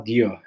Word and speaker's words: Djo: Bueno, siguiendo, em, tenemos el proyecto de Joe Keery, el, mismísimo Steve Harrington de Stranger Things, eh, que se llama Dio, eh, si quieres entender la pronunciación de D-J-O, con, con este disco que se llama Decide.